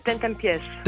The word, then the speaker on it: Ten Tempies: Heb ik het een beetje goed uitgesproken of niet? Ten Tempies? – Volgens mij is het Ten Tempies. – Ten Tempies, oké.